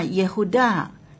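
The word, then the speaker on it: 耶胡大 (יהודה): 犹大的名字的发音呢，耶胡大这个发音呢是犹大，那么赞美是犹杜嘎。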